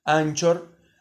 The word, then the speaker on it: Anchor: Eh, si no lo tienen bajado, bájense la aplicación Anchor, que, que en realidad se, en español se escribe Anchor, así como se pronuncia C-H, Anchor.